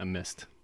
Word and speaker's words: missed: And they look at me and I'm like, yeah, sometimes the ED makes a t sound. And they're like, what? – Why? – What? – Yeah. Yeah. – So I missed. – I missed. Mm-hmm. – Which okay. is how you say M-I-S-S-E-D.